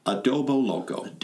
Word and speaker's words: Adoboloco: Adoboloco.